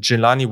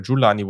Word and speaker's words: Jelani: Wovon ich aber Fan bin, äh, zwei Picks später an 73 haben die Indianapolis Colts Jelani Woods oder Julani Woods, so wird er ausgesprochen, von Virginia gezogen.